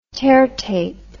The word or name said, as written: teartape